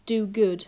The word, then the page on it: do good: English